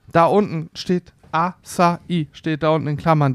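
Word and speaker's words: Açaí: die, Aus, die Aussprache variiert hierzulande von AKI über Asai bis hin zur korrekten Art und Weise Asai. Achai wo, ist natürlich wo, komplett zeig, falsch.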